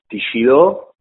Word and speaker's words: Teixidó: Se dice Teixidó y quiere decir tejedor.